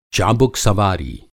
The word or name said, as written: चाबुकसवारी